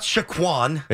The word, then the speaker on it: Saquon: Sometimes there are some, but it is capital S-A-Y, which means that's the emphasis. – Say. – And then dash, lowercase Saquon. K-W-O-N.